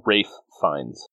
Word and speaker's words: Ralph Fiennes: Rafe Fiennes.